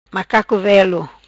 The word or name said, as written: Macaco Velo